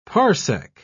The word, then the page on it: parsec: British English